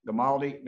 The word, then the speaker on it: Gamaldi: I'm glad to be joined in this episode by Joe Gamaldi.